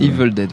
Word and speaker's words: Evil Dead: Evil Dead, voilà, premier du nom.